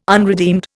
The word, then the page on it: unredeemed: Indian